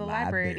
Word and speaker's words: library: I like how you say library. – Library. – Yeah, that's like official. – Oh, okay. – I don't say it like that. – Well, how do you say it? – Library. – There is an extra R in there.